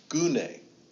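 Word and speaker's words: gune (γυνή): It's G-U-N-E, and it's transliteration, English translation, and it's pronounced gune, gune, okay?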